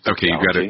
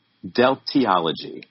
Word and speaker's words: Deltiology: Deltiology. Okay, you've got to pronounce that one more time for me. – Deltiology. – Deltiology. Okay.